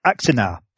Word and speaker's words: Axanar: Let's see if I can pronounce this. Axanar. The way it's spelled is A-X-A-N-A-R. Axanar.